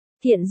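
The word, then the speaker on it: Thiện (单): Tôi tên là Thiện duy nhất, Thiện là từ đa âm, dùng làm họ thì đọc là Thiện, không phải là Đan trong đơn giản.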